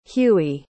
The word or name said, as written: huey